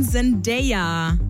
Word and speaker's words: Zendaya: Nein, Zendaya. Also so wird's Englisch ausgesprochen. Zendaya.